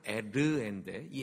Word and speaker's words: 에르: 첫째 이름은 엘이에요. 원래 발음은 에르인데 이 에르라는 말이 깨어있다, 파수꾼이라는 뜻이에요.